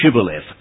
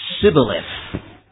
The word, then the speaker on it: Shibboleth: they were told to pronounce the word Shibboleth, S-H-I-B-B-O-L-E-T-H. This was because their dialect couldn't pronounce the SH in the word Shibboleth. And instead, they would say Sibboleth.